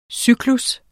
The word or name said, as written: cyklus